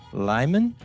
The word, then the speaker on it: Lyman: So it should be a fun chat, though, because we're with the founder, Christopher Pellegrini, and the ambassador, Stephen Lyman.